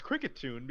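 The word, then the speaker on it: Kricketune: Is that how you pronounce it? Krikatoon? – Yes. – Where's the... – The best cry, it's Krikatoon because it plays a tune.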